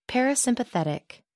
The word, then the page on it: parasympathetic: American English